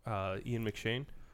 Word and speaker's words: Ian McShane: The big problem ends up being is it creates this clash of what we've seen so far in the movie with Ian McShane.